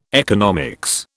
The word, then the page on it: economics: British English